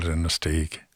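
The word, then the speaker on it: Inistioge: Yeah, you get all sorts of pronunciations, but the locals, we call it Innisteague.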